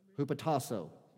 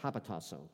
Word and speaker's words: hupotasso: That's the actual pronunciation of it. It's just actually spelled a little bit different. It's actually spelled with a Y instead of an O. It's spelled like hapotasso, but it's hupotasso.